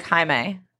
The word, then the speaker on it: Jaime: My name is Jamie's, but it's spelled like Jaime, J-A-I-M-E, and a lot of people spell it J-A-M-I-E, and that is disrespectful.